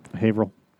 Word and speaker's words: Haverhill: How do you pronounce it? Haverhill? – Haverhill.